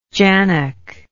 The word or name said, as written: jannock